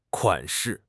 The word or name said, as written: 款式